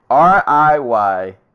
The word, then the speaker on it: Riyadh: Riyada. I can't say this. Riyadh, Saudi Arabia.